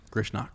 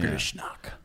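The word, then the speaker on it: Grishnákh: And then Grishnok. – Grishnak. – Is how I would say that. – Yeah. Grishnak.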